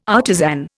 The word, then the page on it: artisan: Indian